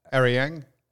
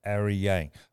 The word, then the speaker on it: Aryang: You ready? A-R-Y-A-N-G. – Ariang. – Ariang. Okay, Sunday Yariang.